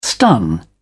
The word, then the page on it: stun: British English